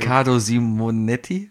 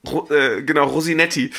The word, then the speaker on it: Riccardo Simonetti: Du musst den Namen schon richtig aussprechen. – Riccardo Simonetti? – Ja, sehr gut. – Heißt das so? – Ja. – Okay. – Wie hat er ihn genannt? Rosinetti.